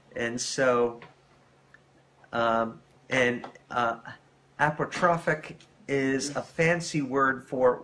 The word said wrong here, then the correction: apotropaic: The way you pronounce it, is there's no H there. – So how would you pronounce it? – Well, apotropaic, I would say.